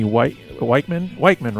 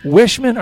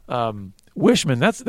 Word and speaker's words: Wiechman: It's actually Wishman. – Wishman, are you serious? And I've been saying it wrong this whole time. – It's okay. Everybody. – But it's, you know, I'm always thinking that that CH should be a hard CH, but...